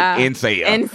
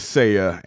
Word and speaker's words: Nseya: And it was spelled N-S-E-Y-A. So a lot of people pronounced it N-Saya. But it was Saya.